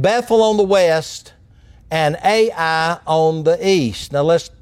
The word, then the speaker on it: Ai: And Ai, spelled in this case of the Old Testament, H-A-I, but pronounced like the two letters Ai, that is an area and town that means ruin.